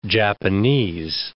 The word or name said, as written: Japanese